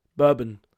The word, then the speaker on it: Bourbon: Bourbon Vanille Aussprache. Was steht denn da? Das ist, das, äh, da es ein französisches Wort ist, Bourbon, wird es Bourbon ausgesprochen.